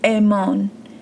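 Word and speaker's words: Amon (אָמַן): In this verse, the Hebrew word translated trust is Strong's 539, and it's pronounced Amon, Amon, which is defined as to be firm, to build up, to support, to nurture, or to establish.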